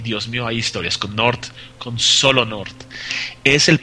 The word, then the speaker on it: G'Nort: Y para las personas que quieran ver una imagen de North, se escribe G'NORT. Y la G, sí, la G es silenciosa.